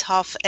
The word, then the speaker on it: Huff: It's Huff as in cough.